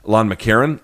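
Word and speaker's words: McEachern: His name is Lon McCarron. It's spelled Lon McKeachern, but it's pronounced Lon McCarron.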